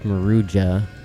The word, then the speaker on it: Maruja: Right now you're listening to Conla's Well by Maruja, which I and many others mispronounced as Maruja for a long time, but that's Maruja. That's a J. It's a J.